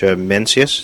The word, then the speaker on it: Mencius: In the book, you tell this anecdote about the Chinese sage Mencius. Did I pronounce that correctly? – Mm-hmm.